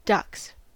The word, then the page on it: ducks: American English